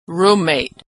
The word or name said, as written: roommate